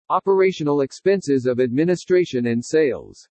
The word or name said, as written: OPERATIONAL EXPENSES OF ADMINISTRATION AND SALES